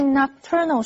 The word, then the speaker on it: nocturnal: 我可以冒昧的提一个建议吗？接着我们看 nocturnal 这个形容词，注意它的重音是在 T-U-R 这个音节，念成 nocturnal。N-O-C-T 这个字根有晚上的意思，所以 nocturnal 是指夜间的或者是夜间活动的。例如 Most nocturnal animals have good eyesight，大多数的夜行动物都有很好的视力。或者是 The medicine claims to reduce the occurrence of nocturnal asthma.